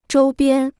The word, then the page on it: 周边: zhōu biān